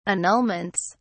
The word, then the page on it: annulments: British English